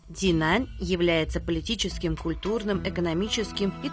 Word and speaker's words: 济南: Ди читается в третьем тоне. Это древнее название реки, которая протекает по территории города. Нань читается во втором тоне, означает юг. Динань, то есть на южном берегу реки.